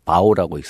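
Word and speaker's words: 바오 (报): 정보 할 때 한자로 보 알릴 보자를 아. – 중국어로 BAO 바오라고 읽습니다.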